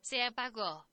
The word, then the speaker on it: Se Apagó: And with a little help, Se Apagó. – There we go. That's how you pronounce it.